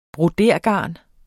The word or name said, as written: broderegarn